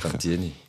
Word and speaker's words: Cantini: Ich weiß nie, wie ich seinen Nachnamen aussprechen muss. – Cantini. – Cantini drin. So wie Fieri. – Ah ja, stimmt, wie Fieri. Cantini.